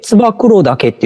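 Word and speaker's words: 燕岳: これ、ツバメダケと書いて、ツバクロダケって読むの？ – あ、そうです。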